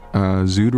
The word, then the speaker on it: Sued: Yeah, so most of these records that I played were from this label called Zood Records out of Berlin. It's spelled S-U-E-D.